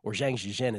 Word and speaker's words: Zhang Zhizhen: And Zhizhen Zhang, Zhang Zhizhen is actually how you say it, who we won on yesterday very easily, easy peasy match.